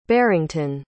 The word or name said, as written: barrington